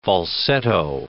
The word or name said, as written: falsetto